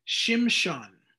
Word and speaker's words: Shimshon: His name in Hebrew is Shimshon. Now, that's not me trying to make some kind of weird like Chinese pronunciation or something like that.